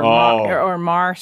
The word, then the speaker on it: Meagher: There's a bar in Missoula called the Thomas Mar Bar. – Hmm. – Yeah. So that's spelled meager. – Yeah. – M-E-A-G-H-E-R.